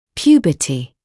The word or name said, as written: puberty